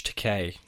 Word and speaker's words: Takei: Star Trek veterans, George Takei, to rhyme with toupee, and Nicholas Meyer, who directed Star Trek II, The Wrath of Khan, were not fans of this interpretation.